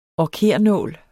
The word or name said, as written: orkernål